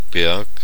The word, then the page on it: hill: /hɪl/; [hɪl]